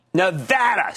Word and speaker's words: Nevada: Nevada. See, it's pronounced Nevada, Regina. She's always fighting with me on that.